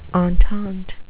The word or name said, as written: entente